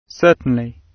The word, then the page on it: certainly: British English